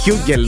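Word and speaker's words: Hugel: Α, είναι ο Χιούγκελ ή Χιούτζελ. Χιούγκελ νομίζω προφέρεται. – Χιούγκελ και εγώ τον λέω.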